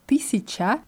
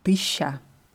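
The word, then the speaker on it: тысяча: Jetzt ist es so bei dem Wort tisica, das wird so richtig ausgesprochen. Nur in der Umgangssprache hört man oft tisha. – Tisha. – Tisha. Und das ist kein Dialekt, sondern es gibt Leute, die dieses Wort eher deutlicher aussprechen, aber es gibt auch sehr, sehr viele, die das als Tisha aussprechen.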